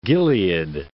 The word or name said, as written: Gilead